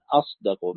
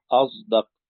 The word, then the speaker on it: أصدق: ثم ذكر في قوله تعالى: ومن أصدق من الله حديثا، قراءة حمزة والكسائي، قال: وكل صاد ساكنة بعدها دال بإشمام الزاي، هكذا تقرأ أصدق، كما ينطق العوام المصريين أصدق بإشمام الصاد الزاي، وهي قراءة متواترة.